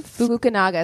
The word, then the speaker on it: Fukunaga: Fukunaga is what it looks like.